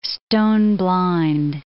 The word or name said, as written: stone-blind